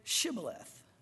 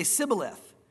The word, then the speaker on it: Shibboleth: Notice, and they would say Sibboleth. And so it says, for he could not pronounce it right.